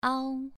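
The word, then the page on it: ao: tone 2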